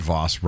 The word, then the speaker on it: Voss: I understand that Voss rhymes with toss.